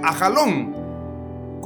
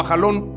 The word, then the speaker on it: Ajalón: O en otra versión dice Ajalón. Como lo quieras pronunciar, Ayalón o Ajalón es correcto.